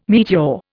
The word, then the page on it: meteor: Indian